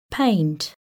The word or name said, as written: paint